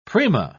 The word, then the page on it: prima: British English